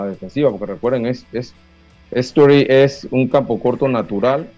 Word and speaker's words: Story: Eh, es así que se pronuncia Lucho, ¿no? – Story, story, story, story.